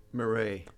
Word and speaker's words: Marais: Marais.